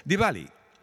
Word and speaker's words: Diwali: And the V and the W, if I want to really say it the way I try to say it correctly, Diwali. The W and the V kind of come together, but it is not Diwali.